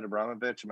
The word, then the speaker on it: Abramovich: I feel that Chelsea's board, or just Roman Abramovich. That's his last name, right? Abramovich. Am I saying that right? – Yeah.